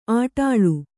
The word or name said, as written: ಅಡಳು